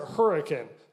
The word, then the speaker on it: Hurricane: And we have a city in southern Utah spelled hurricane. We pronounce it hurricane for no reason at all, right?